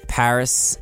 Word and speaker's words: PVRIS: That's Paris spelled P-V-R-I-S, caps lock.